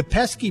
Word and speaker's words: Pesky: It's P-E-S-K-Y. And that's Pesky, not Pesci.